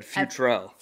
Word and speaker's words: Futrell: Futrell.